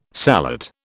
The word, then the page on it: sallet: British English